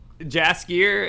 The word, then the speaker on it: Jaskier: Jaskier, is that how you say it? Jask with a J? There you go.